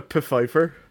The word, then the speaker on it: Pfeiffer: It's not even spelled the same way, although it does have the silent P before it. – Yeah, it's got a Pfeiffer.